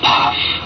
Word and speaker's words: Pfaff: P-F-A-F-F spells faff, pronounced in Thai, PAF.